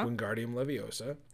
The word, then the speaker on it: Wingardium Leviosa: Wingardium Leviosa.